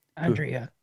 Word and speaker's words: Andrija: Andrija. Uh, Andrija yeah.